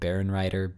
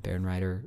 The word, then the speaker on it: Bärenreiter: but Barenreiter, B-A-R-E-N-R-E-I-T-E-R, EITER, Bärenreiter Urtext editions.